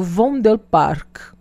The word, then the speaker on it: Vondelpark: Para você que gosta de passear, você não pode deixar de dar uma volta no Vondelpark. É, por aí. Se escreve assim do jeito que eu falei, tá?